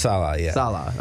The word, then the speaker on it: Saleh: I really like Robert Saleh. – It's pronounced Salah.